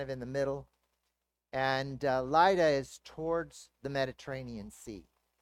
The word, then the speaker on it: Lydda: or Lydda, however you want to pronounce it, right, Dave? So he comes down to Lydda. I was pronouncing it Lydda, so I'll go with Dave's pronunciation.